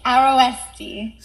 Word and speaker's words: Aroeste: Aroeste.